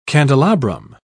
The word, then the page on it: candelabrum: British English